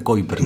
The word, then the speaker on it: Kuiper: Nosotros le decimos en español Kuiper, pero debiera pronunciarse Kuiper, ¿no? – Kuiper, que el, tipo era holandés, así que un holandés te lo va a pronunciar de, forma más exótica.